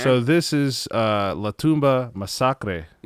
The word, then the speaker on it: Masacre: It's spelled just like the English word massacre. It's called Masacre.